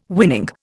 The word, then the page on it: winning: Indian